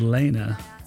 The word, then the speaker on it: Lene: And I know it's pronounced Lena because there is a, I think, a phonetic spelling on her first album on the cover, which I thought was quite handy because I always thought it was Lene.